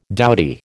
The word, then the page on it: dowdy: American English